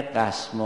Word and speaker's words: قسم: یکی از چیزهایی که به اون تعبائل به طلب ها میاموختن همین فرق بین قسم و فسم بود، که قاف و فا در تلفظ یکسان نیستن، معنای قسم و فسم هم یکسان نیست. اون شکستنی که سر صدا داره و اجزایش کاملا جدا میشه با قاف ادا میشه میگن قسم.